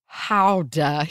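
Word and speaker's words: Gouda: I can indeed confirm that the cheese is pronounced howda here with a guttural GH sound, which, yes, I know I didn't do that, but I gave an attempt.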